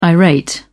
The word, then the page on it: irate: British English